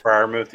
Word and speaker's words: Freiermuth: Freiermuth, is that how you pronounce his name? Freiermuth, yeah.